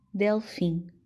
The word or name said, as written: delfim